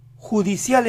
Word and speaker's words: judicial: Judicial empieza así, ju, ju. Díganlo con una Y marcada, ju. Nunca lo digan como si empezaran con I, yu. Tiene que ser ju. La siguiente sílaba es de, de. El inicio entonces es yudé, yudé, yudé. La palabra completa, judicial, judicial, judicial.